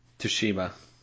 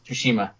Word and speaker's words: Tsushima: Tsushima. – Tsushima, thank you.